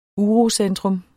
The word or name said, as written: urocentrum